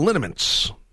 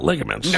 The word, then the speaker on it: liniments: 3-2-1. No, I just take a lot of liniments, not ligaments.